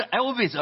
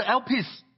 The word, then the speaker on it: Elpis: 事实上韦恩对盼望的定义，希腊文的盼望是，想想猫王， OK，你就会明白这一点，就能记住这个 Elpis，试着说 Elpis，所以不是 Elvis 而是 Elpis。我不该提猫王的，不要把 P 音念成 V 好吗？